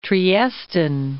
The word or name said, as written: Triestine